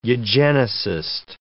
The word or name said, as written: eugenicist